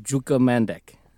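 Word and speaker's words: Đuka Mandić: I looked up the pronunciation for this woman's name, and it was Juka Mandic.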